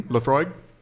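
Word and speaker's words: Laphroaig: Lafroy. – Yes, and that's what I was going to talk about pronunciations because you just pronounced that correctly.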